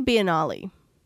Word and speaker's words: Biennale: Okay, so the first thing first, we are going to, how do you say it? Like Biennale or Biennale? – I always say Biennale.